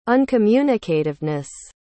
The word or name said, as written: uncommunicativeness